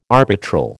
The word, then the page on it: arbitral: American English